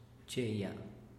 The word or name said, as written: cheya